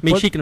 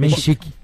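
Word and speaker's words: Menšík: A KTO tá pagando agora pro Jakub Mensik, é isso? – É. – Como é que foi pronunciado? – Mensik, na verdade. – Mensik.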